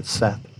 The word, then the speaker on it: saith: Did you know the proper pronunciation of Seth? It looks like it should be saith, but Seth.